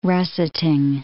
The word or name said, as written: russetting